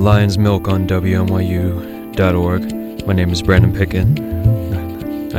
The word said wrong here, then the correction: Peckman: My name is Brandon Peckman.